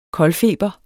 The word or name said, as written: koldfeber